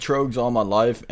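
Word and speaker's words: Tröegs: Trogues? Is that how you pronounce it? – I've said Trogues all my life, and if we're being specific, it's Trogues Perpetual IPA.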